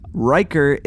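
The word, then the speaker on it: Reicher: Riker. – Riker? – Riker. – Does that ring any kind of significance to me? – Since you actually have a primordial deity, yes, it does. Riker is essentially a blood priest.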